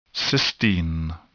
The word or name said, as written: cysteine